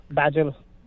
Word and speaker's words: Baijal: Gaurav Bajal. – How do you say that? – B-A-I-J-A-L, Bajal. It's a very unique last name. – Very different, yeah. B-A-I-J-A-L. – J-A-L.